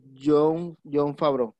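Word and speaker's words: Jon Favreau: Quien me sorprendió escuchar Joe Fravo, creo que se llama así, deja y lo busco para pronunciarlo bien. – El creador. – El creador, sí, se llama John Favreau.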